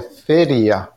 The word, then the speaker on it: feria: Fiera. Is fiera fair? – No, fiera no. It sería feria. – How do you say fair? Feria.